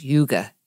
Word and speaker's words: hygge: It's spelled H-Y-G-G-E, and I think it's yuga, yuga.